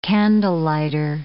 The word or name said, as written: candlelighter